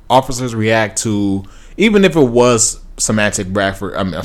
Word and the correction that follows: Emantic Bradford: mispronounced his name, Emantic Bradford.